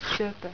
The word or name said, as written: sopo